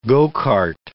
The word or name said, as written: go-cart